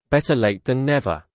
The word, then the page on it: better late than never: British English